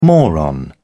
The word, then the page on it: moron: British English